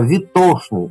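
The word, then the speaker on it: ветошный: И мы видим витошка, витошников, витошник, «А, витошный. Ударение именно на букву О, а не на букву Е, как это принято сейчас.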